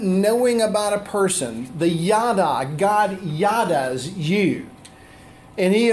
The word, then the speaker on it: yada: As a matter of fact, the word in the scripture that we're going to focus on, the word K-N-E-W, knew, or know, K-N-O-W, is the Hebrew word yada. Yada, Y-A-D-A. I can spell that. Yada. Not yada, but yada.